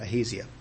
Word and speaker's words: Ahaziah: That's the way I'm pronouncing it for the rest of my sermon, Ahaziah.